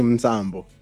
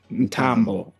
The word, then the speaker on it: Mtambo: You were saying Mzambo. – Oh my God, guys. Give me an M or an N. Take away the T at an H. I don't know. – Welcome back. – Welcome back. – I missed you guys, eh? So how did I say it wrong? – You said Mzambo. – We said Mzambo. – Okay, so then it's? – Mtambo. – What? Okay. – It's just, there's no S.